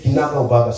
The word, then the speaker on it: kinaga ubaga: Bado tutaiona kinaga ubaga. Is that the correct way to say it? – Yes. – Kinaga ubaga.